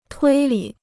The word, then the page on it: 推理: tuī lǐ